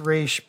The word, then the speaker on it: Ra's: It's actually, I mean, they say Ra's in the movie and there's debate. – It's Ra's, but it's... – But anyone of Middle Eastern descent will tell you it's Ra's.